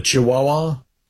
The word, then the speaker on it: Chihuahua: Chihuahua? – Chihuahua, thank yeah.